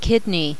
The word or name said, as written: kidney